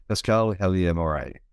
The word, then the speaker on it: Pascale Helyar-Moray: So today I'm speaking to, I'm hoping I get the right pronunciation right here, but Pascale Helyar-Moray.